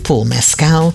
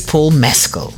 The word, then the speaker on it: Mescal: He's not Paul Mescal, he is Paul Mescal. I found out in a conversation on YouTube between him and another actor, Andrew Scott. So it's Paul Mescal.